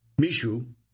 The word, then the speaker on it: Mixue: Last year, Mixue, pronounced Mishu, assembled managers and franchises in Singapore, said people familiar with the gathering.